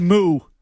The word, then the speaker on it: moue: M-O-U-E. – Moo. It's pronounced moo, like moo.